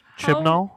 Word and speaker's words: Chibnall: Yeah, it's Chris Chibnall.